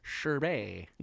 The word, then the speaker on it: sherbet: Sherbert. They spelled Sherbert wrong. That's why I got confused. – Sherbet. – That's how it's spelled. S-H-E-R-B-E-T.